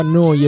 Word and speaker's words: Neu: Neuradio si scrive Neu, si pronuncia Noi e vuol dire nuova, nuova emittente urbana.